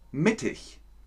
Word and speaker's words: mittig: IG geschrieben, aber ch ausgesprochen. 90. Sehr schön. Heute zehn Wörter mit ch am Ende. Zehn IG-Wörter. Zum Beispiel mittig.